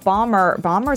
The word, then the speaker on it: Bomer: And besides, also, Matt Bomer, is that how you say it? – Bomer. Yes.